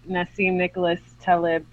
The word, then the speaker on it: Nassim Nicholas Taleb: I was like, well, that's a really great segue into the next book, Black Swan, by Nassim Nicholas Taleb. Did I say that right? – Yes.